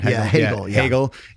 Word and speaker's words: Hegel: Because I know one thing you spend a lot of time in the book talking about is Marxism, Hegel, am I saying his name right? – Hegel.